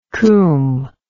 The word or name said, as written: coom